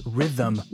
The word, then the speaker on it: rhythm: rhythm also causes problems, by the way. – Just divide it into two syllables rhythm, rhythm. It looks more difficult to pronounce than it is.